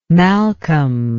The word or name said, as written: malcolm